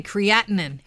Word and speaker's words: creatinine: The actual pronunciation is creatinine. – Okay, creatinine.